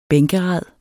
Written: bænkerad

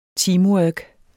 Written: teamwork

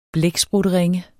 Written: blæksprutteringe

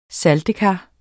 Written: saltekar

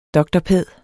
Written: dr.pæd.

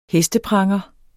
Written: hestepranger

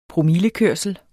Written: promillekørsel

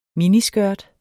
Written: miniskørt

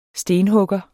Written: stenhugger

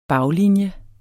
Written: baglinje

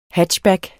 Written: hatchback

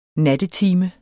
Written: NATTETIMEN

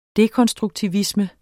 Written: dekonstruktivisme

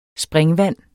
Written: springvand

